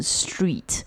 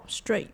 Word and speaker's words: straight: 如果你要说 walk straight 可以啦、可是 walk straight 不能把它 straight 念成 street 哦、 oh, straight